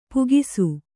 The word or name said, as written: ಪೀಕಿಸು